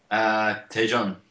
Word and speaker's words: Daejeon: Daejeon? How do you pronounce that, Don? – Daejeon.